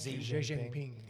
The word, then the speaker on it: Xi Jinping: Xi Jinping. – Xi Jinping.